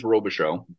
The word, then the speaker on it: Robichaud: A little bit, but it's the name is Robichaud, but it's a French Canadian name.